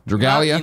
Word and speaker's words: Dragalia: Dragalia.